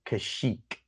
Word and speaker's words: Kashyyyk: Chewbacca is a Wookiee from the planet Kashyyyk, but Chewbacca lives on the planet Andor. Now think about that. – Hold on. – That does not make sense. – The nerd is coming out here. It's Kashyyyk.